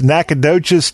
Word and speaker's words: Nacogdoches: Nacogdoches has a lot of letters in there that don't come into play whatsoever.